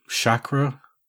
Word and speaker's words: Chakra: Chakra. I think that's how you say it. But, yeah, that's right.